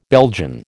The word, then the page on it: Belgian: American English